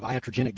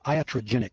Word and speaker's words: iatrogenic: All right, we've been talking about this thing of iatrogenic disease.